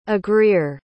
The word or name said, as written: agreer